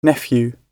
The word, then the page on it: nephew: British English